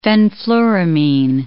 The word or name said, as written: fenfluramine